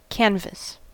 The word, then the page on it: canvas: American English